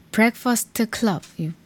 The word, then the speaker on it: Breakfast Club: Black First Club의 Ride on Track이었고요. Pet Shop Boys의 Being Boring까지 띄워드렸어요. Breakfast Club. 발음이 왜 이렇게 꼬이죠?